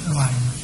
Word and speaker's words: đoàn: Thực năng bất đoàn thực, chuyện này đừng có đọc là chữ bát, nên đọc là chữ đoàn.